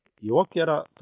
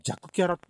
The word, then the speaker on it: 弱キャラ: 続きましてはですね、弱キャラ友崎くんセカンドステージ。これ弱キャラって読むらしいんですけど、まあ、どちでもいいや。